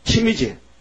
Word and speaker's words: 침: 수염에 침이 흘러가서 숨이, 춤이 딱, 춤이 붙으면은 저 사람 볼 때 이놈 미쳤구만 그려. 춤도 관리 못하는 분이게. 침. 침이지. 응? 침이죠. 춤이 아니지. 침이지. 난 발음이 좀 다르다니까.